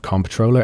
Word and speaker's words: comptroller: Welcome back to FinTech Insider News, where we have Googled the definition of comptroller, and it is in fact a controller. – With a silent P. – With a silent P. – How silent is it though? Comptroller, it's an awkward word.